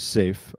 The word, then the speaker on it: Seif: And I got to ask you, you spell your name S-E-I-F. – Good question. Great question. My given name is Safe, fairly common, common enough Arabic name, which I spell S-E-I-F.